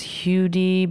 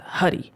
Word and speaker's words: Huddie: So Huddy, Huddie, and I guess people thought it was Hudy, but he pronounces it on a track, the Bull Weevil track, and he says Huddy.